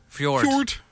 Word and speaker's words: Fjord: Aside from Fjorn. Fjord. Yeah. – You're going to have to say it like Swedish Chef.